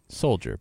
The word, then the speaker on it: Souljah: In case you're wondering, the name is spelled S-O-U-L-J-A-H. Souljah, but Souljah. – Yeah. – It's a play on words.